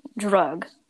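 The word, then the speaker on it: drug (друг): Oh, and the P, the Russian word for friend is literally, listen to this, drug. – I mean, listen to drug.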